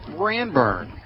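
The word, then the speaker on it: Ranburne: Ranburn. We're in Ranburn, Alabama. – Ranburn? – Ranburn. – Oh, Ranburn. I thought there was a D in it.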